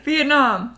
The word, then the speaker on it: Vietnam: I don't know why I said Vietnam. I don't think that the enunciation is on that syllable, but it's okay, it's late, and I don't care. Vietnam, there, anyway.